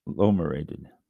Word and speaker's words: Agglomerated: This is also known as an now I started to say it wrong and I can't say it right. Agglomerated. Boy. Agglomerated. Agglomerated. There, that's closer than I'll get.